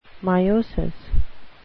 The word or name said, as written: meiosis